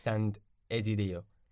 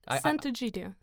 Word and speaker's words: Sant'Egidio: And how did you join the organization you're working right now, Sand Egidio? Am I uh, saying it right? – Sant'Egidio.